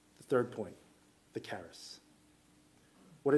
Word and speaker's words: charis: Okay, I had to stick with the Greek in order to maintain the hard C alliteration, right? Culture, covenant, charis.